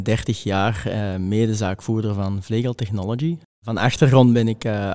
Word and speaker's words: Vlegel Technology: En eh, jij werkt bij Vlegel Technology, zeg ik dat goed? Ja, correct ja.